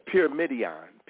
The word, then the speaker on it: pyramidion: Yeah, pyramidion.